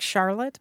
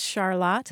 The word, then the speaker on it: Charlotte: Remember our conversation where I was talking about going to Vermont and visiting the town of Charlotte and mm-hmm. being surprised that it looked like Charlotte, but mm-hmm. it's pronounced Charlotte? – Mm-hmm.